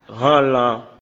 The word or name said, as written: viande de porc